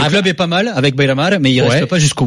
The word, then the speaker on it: Beira-Mar: Tu me dis si je me trompe sur les prononciations, mais c'est à Chaves, c'est une montée de 3e en 2e division à Beira-Mar, c'est ça? On est, je suis bien. – Club d'Aveiro, ouais. – Beira-Mar, c'est bien.